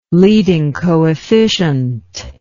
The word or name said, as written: leading coefficient